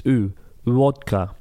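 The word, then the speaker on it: U. Vodka (ウォッカ): Vodka in Japanese is vodka. It's, you don't pronounce the V or the B. It's like, it's U. Vodka.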